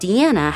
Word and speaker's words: Deana: So for consistency's sake, we're pronouncing her name Deana, which is how detectives pronounce it. Anyways, even before the results of the test came back, investigators were pretty convinced that Deana was indeed their Jane Doe.